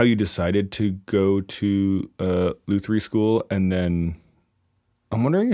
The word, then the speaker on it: luthiery: You went to luthiery school. Is that how you say it? – Luthiery.